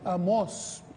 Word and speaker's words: Amós: No, no, Amós, tiene un acento en la O.